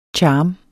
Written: charm

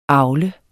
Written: avler

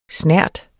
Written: snert

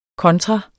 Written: kontra

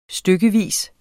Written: stykkevis

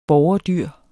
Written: borgerdyr

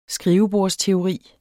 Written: skrivebordsteori

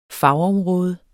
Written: fagområde

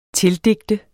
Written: tildigte

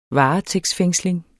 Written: varetægtsfængsling

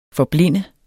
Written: forblinde

